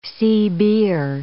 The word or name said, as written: CBer